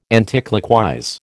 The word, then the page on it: anticlockwise: American English